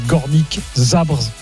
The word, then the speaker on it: Górnik Zabrze: Je sais pas si je prononce bien, le Górnik Zabrze. – L'accent est pas mal en tout cas.